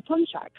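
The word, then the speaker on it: pączek: It's said Poonchki, but when you say Poonchki, you're actually referring to more than one. That is the plural. An individual one is actually called a Poonchek.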